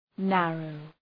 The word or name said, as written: narrow